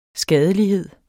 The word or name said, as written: skadelighed